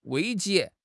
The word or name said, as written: 危机